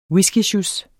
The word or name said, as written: whiskysjus